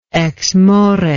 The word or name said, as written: exmore